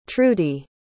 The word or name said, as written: trudi